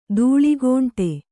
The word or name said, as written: ದೂಳ್ಗೋಂಟೆ